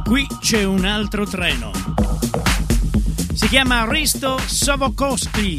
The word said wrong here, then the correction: Sokolovsky: Detto bene? Sokolovsky. Ah, capito. Sokolovsky.